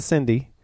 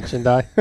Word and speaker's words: Chindi: Not the Cindy. – Not the Cindy. – Not the Chindi. It's the Chindi. – The Chindi.